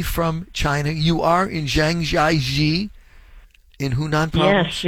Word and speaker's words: Zhangjiajie: So, Zhangjiajie. Three separate words, this community, this city in Hunan province.